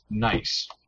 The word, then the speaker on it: gneiss: There's a rock that's spelled G-N-E-I-S-S or something like that. – Yeah. – Oh. That's pronounced nice.